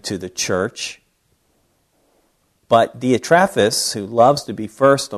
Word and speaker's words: Diotrephes: I refer to him, I pronounce his name as Diotrephes. Some will say Diophantus. But I say Diotrephes, same guy.